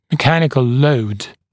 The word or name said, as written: mechanical load